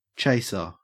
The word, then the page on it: chaser: Australian English